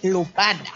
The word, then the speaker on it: Lupin: Es por eso que eh, tiene una pronunciación diferente. Entonces se dice Lupin.